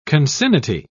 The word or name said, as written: concinnity